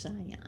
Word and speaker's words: scion: 14 down descendant was a scion, or however that's pronounced. And I think it's scion. – Scion. – I think the C is silent.